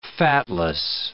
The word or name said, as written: fatless